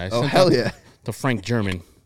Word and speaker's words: German: So, obviously with a lot of players, there's a pronunciation guide that comes along with their name when they are acquired. And, hey, right there in the press release when the Sox made that deal for the former Red Sox pitching prospect, there it was, pronunciation guide, German.